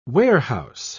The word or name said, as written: warehouse